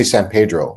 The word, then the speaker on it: San Pedro: When in the city we grew up in, like San Pedro, or, you know, so people are like, why don't you say San Pedro? You can pronounce it Pedro, but I'll know you're not from there.